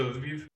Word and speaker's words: Lviv: Is it Lviv? Is that kind of like a safer area than? – Yeah. So Lviv, yeah, there's a lot of people that go to Lviv.